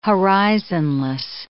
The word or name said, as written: horizonless